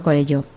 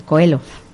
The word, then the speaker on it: Coelho: Decía Paulo Coelho, o como se pronuncia, Coelho.